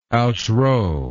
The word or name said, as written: outrow